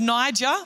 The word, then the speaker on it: Niger: Simon called Niger, I'm hoping that's how you pronounce that, like Nigeria, Simeon rather, sorry.